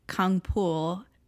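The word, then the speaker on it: Kang Full: It is adapted from the webtoon Moving by Kang Ful, aka Kang Pool. It, I guess, just depends on how you pronounce it. There is no F sound in Korean.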